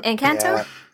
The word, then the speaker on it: Encanto: Encanto? Is that how you say it? – Uh-huh. Encanto? – Yeah.